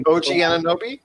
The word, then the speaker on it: Anunoby: So you need that strong athletic defender, and I think like OG, uh, uh, I can't pronounce his last name from OG Anunoby.